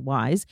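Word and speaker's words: Ysé: It's spelled Y-S-E, but pronounced Wyse.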